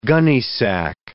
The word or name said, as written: gunnysack